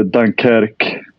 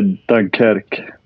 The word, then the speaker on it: Dunkerque: På franska är det Dunkerque. – Vi andra säger väl Dunkerque snarare, va? Vi som är lite mer anglofona. – Precis. På engelska är Dunkirk då,